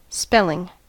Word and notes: spelling: American English

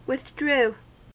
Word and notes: withdrew: American English

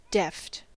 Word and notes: deft: American English

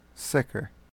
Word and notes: sicker: American English

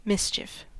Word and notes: mischief: American English

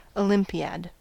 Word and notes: olympiad: American English

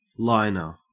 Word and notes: liner: Australian English

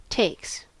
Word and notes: takes: American English

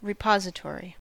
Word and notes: repository: American English

